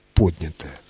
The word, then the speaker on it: поднятое: Поэтому, конечно же, поднятая целина исключено категорически. Правильное ударение – поднятое.